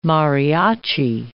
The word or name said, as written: mariachi